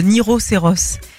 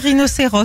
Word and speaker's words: rhinocéros: Le plus petit, qui est déjà grand, mais c'est Niroceros. Il n'arrive pas à dire rhinocéros, c'est le Niroceros.